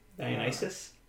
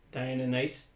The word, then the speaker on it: Deinonychus: Deinonychus. – Dionysus? Deinonychus? – Because this isn't it.